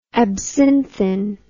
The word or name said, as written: absinthin